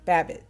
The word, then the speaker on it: Babbitt: Let me get her name right, because it sounds like rabbit. Babbitt, thank you, in my brain.